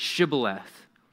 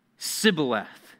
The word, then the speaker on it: Shibboleth: And they, the men of Gilead, said it a certain way, and they said it and pronounced it Shibboleth. But guess what? Men of Ephraim said it differently, and he said it Sibboleth, for he could not pronounce it right.